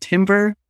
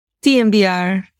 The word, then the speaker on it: TMVR: So Dr. Guerrero, as the expert, do you pronounce it timber or TMVR? – TMVR.